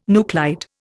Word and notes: nuclide: Indian

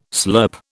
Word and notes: slurp: British English

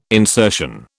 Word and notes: insertion: British English

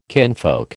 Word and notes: kinfolk: American English

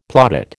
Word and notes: plaudit: American English

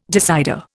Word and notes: decider: Indian

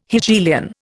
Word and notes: Hegelian: Indian

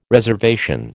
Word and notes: reservation: American English

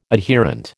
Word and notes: adherent: American English

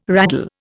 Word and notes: raddle: Indian

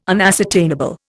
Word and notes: unascertainable: Indian

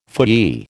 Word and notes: phooey: American English

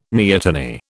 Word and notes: neoteny: British English

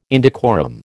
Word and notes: indecorum: American English

